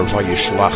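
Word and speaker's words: Vayishlach (וישלח): This show is for Shabbat Parshat Vayishlach, or Vayishlach, as it is correctly pronounced.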